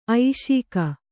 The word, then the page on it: ಐಷೀಕ: Kannada